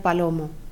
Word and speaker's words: Palomo: Me llamo María García Palomo. – ¿Cómo se escribe su nombre? – M-A-R y con acento A. G-A-R-C y con acento A. P-A-L-O-M-O.